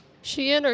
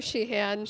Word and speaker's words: Sheehan: Sheehan or Sheehan.